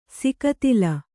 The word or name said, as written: ಸಿಕತಿಲ